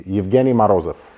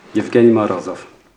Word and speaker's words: Evgeny Morozov: But uh, the proper Russian version would be Evgeny Morozov. Evgeny Morozov.